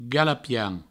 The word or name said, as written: galapian